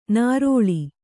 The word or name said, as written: ನರಳು